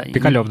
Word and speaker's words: Пикалёв: Я слушаю, я ты знаешь, я Пикалев, да.